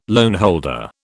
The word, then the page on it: loanholder: British English